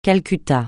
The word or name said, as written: Calcutta